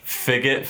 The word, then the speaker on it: Phygit: What's a figgit? Fidget. Pronounced like fidget.